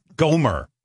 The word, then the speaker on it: Gohmert: Gohmert, like Gohmert Pyle.